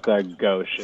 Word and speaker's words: Ghose: Why don't you help me pronounce that, brother? – It's pronounced Ghosh. It's one of those.